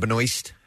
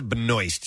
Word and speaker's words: Benoist: Melissa, do you pronounce her last name Benoist? – I do. – Supergirl? – Yes. – Okay. Melissa Benoist.